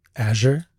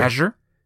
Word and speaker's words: Azure: Am I saying that right? – Azure? Sure. – Azure? – Yeah.